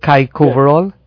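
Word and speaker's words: Kyk-Over-Al: Is it Kaikoverall? – Yes, Kaiko, K-Y-K.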